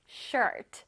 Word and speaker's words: shirt: 근데 우리는 그냥 셔츠라고 발음 안 하고 어떻게 한다고? Shirt. Shirt. 해볼래요? Shirt. Okay. 자, 보다시피 여기 다 빨간색으로 되어 있네. 전체적으로 문장에 힘을 줘서 발음해야 됩니다. Shirt. 해보세요. Shirt. Alright. 자, R이 있기 때문에 혓바닥이 어디 있어요? 앞에 있어요? 뒤에 있어요? 그렇죠. 뒤에 있어야 돼.